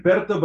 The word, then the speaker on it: Berto: Yes, it's like Berto, but you know what? People say it in a lot of different ways. I accept whatever you call me.